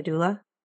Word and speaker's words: doula: A doula? – Yeah.